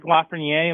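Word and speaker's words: Lafrenière: Number one overall, Patrick Lafreniere.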